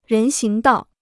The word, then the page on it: 人行道: rén xíng dào